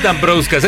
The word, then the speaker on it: Dambrauskas: Valdas Dambrauskas. – Según yo es Dambrauskas. Es que es lituano.